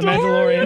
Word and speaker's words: Mandalorian: The Mandalorian.